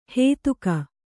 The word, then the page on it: ಹೇತುಕ: hētuka